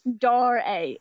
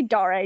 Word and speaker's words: Dare: I used to say it in videos, and all of my comments were, it's pronounced Dare. It's pronounced Dare. Just tons of I get emails about it. I used to get emails about it. It's pronounced Dare, just so you know. So I say Dare.